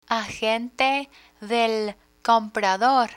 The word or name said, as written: agente del comprador